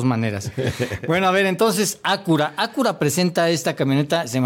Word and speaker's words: Acura: Pues mira, la connotación en español pues es Acura. – Uh-huh. Pero gracias. – En inglés, pues es Acura por la...